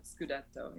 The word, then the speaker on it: Scudetto: I was about to say you'd have another Scudetto. Is that correct? – That's correct. Yeah, the Italian championship is called the Scudetto.